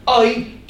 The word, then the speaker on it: Ai: And he set up camp in the hill country between Bethel to the west and Ai, which is in a way how you pronounce that name, Ai to the east.